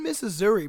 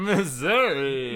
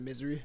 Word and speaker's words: Missouri: Missouri. – No, but they do... It's called Misery. A lot of people pronounce it Misery. Missouri. Like it's Misery. No, that's a super Texas accent.